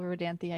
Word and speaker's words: Rodanthe: And it is three-syllable Rodanthe. I Googled it because I didn't know how to pronounce it.